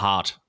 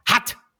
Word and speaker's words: Heart: Ähm, Herz auf Englisch konntest du nicht aussprechen. Das heißt ja hart. Und du hast immer gesagt, hat, hat. Heartbreaking. Hab ich gesagt, nein, das heißt hard. Nein, hat, hart.